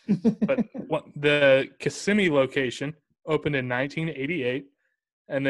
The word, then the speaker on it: Kissimmee: Yeah, so a lot of people say Kissimmee, but it's really Kissimmee. So it's kind of the end of that, those S's, that's really kind of like emphasizes the syllable and whatnot.